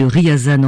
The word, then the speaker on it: Ryazan: Je rectifie donc la prononciation de la ville de Ryazan en Russie.